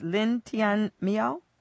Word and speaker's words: Lin Tianmiao: One in particular that we both seem to like is Lin Tianmiao. Is that how you pronounce her name? Yeah, she's very good.